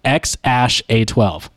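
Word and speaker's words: X Æ A-12: So it was in an interview with Joe Rogan where he said it is pronounced X Ash A 12.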